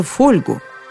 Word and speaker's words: фольга: Или, как она сама выражается, фольгу – не пугайтесь, я всего лишь воспроизвела тетушкино ударение в этом слове. И не удивляйтесь, она знала, что говорит. Есть еще люди, которые произносят слово «фольга» именно так – «фольга».